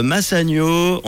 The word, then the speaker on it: Massagno: Il y aura aussi Morges, Yverdon, Fribourg, Vevey, euh, Massagno. On, dit, on prononce comme ça? – Oui, c'est juste, c'est juste.